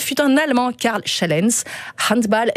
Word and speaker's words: handball: Prononcé comme le petit bal du samedi soir. On ne dit pas handball, on dit handball.